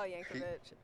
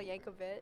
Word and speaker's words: Yankovic: Weird Al Yankovic is is it Weird Al Yankovic? – Yankovic.